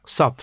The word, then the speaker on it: SAP: Je bent eerst als speler, denk ik, bij SAB. Zeg ik dat goed? SAP. SAP. SAP in Breda, ja.